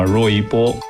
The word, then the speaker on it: 罗一波: 原名的发音应该是叫罗一波。